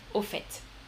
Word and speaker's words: au fait: Oui, Trainador, pour toi c'est intéressant qu'on prononce le T final, c'est vrai. Euh, c'est vrai, normalement on ne devrait pas prononcer hein, le verbe faire, on dit je fais, tu fais, il fait. Mais euh, là on prononce le T, mhm, au fait.